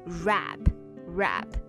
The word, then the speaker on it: wrap: 嗯，那这里呢，大家还要注意 bubble wrap 里面这个 wrap。W R A P 它的发音，这个单词开头是 W 开头，但是这个 W 啊是不发音的，所以你直接读成 rap rap bubble wrap。